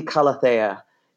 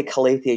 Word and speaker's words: Calathea: It would be Calathea if you were pronouncing it closer to the true Latin or Greek. But in English, you could say Calathea. You're just shifting the emphasis.